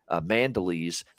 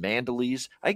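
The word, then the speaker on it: Mandolese: I guarantee you, if you're a true blue Italian, he's Kevin Mandelisi. That's how you say the name. Kevin Mandelisi. That's how you say it. That's the Italian way to say it. But I think here in North American English, we're calling him Mandelis right now for the Ottawa Senators.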